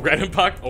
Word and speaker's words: Redenbacher: Am I saying his name correctly? – Dave? – No. The guy who makes popcorn. – Oh, Redenbacher? Orville Redenbacher? Orfield? Yeah, I think you're good.